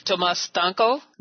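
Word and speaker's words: Tomasz Stańko: One, you're going to be with Tomas Stanko. – Right. – Am I pronouncing that correctly? – Yeah, that's correct.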